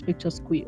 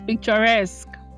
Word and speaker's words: picturesque: Is it the one that I've been pronouncing as picture square? Or there's another one that is actually picture brace. Hey, God, you guys. So, yes, it's picturesque. Picturesque.